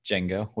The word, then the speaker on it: Django: Django, with the D is silent.